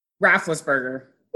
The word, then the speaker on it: Roethlisberger: I saw him the day the polls open, and he was taught, that's Roethlisberger, right? – Roethlisberger.